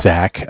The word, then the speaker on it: Zach: My name's Zach, spelled Z-A-C-H.